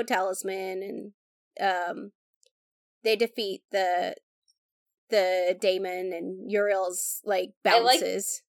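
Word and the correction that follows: Eudial: Udiel. You keep calling her Uriel, and it's Udiel.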